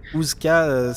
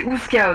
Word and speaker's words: Oozka: Ouska, ça s'écrit O-O-Z-K-A. – Ah oui, ok. – Kazoo, plus de l'or. – Ouais. – Ok. – Non, c'est pas comme Kazoo, c'est Ouska, ok.